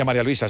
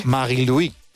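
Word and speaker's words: Marie-Louise: No se dice María Luisa, se dice en su... – Marie-Louis. – Marie-Louis Eta, ¿no? – Así es.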